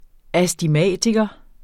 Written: astigmatiker

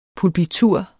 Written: pulpitur

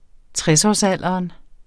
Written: tresårsalderen

